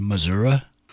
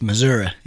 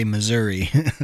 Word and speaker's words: Missouri: And I hate to correct you, but it's pronounced Missouri. I don't know what it is about the old timers around here. It's Missouri, and they get pissed if you say Missouri.